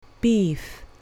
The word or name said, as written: beef